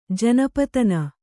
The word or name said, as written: ಜನಪತನ